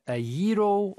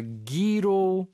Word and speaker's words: gyro: But a gyro or a gyro. – Zero. – Or gyro. – Yes. – I think there are a few pronunciations there.